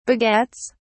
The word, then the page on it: begets: British English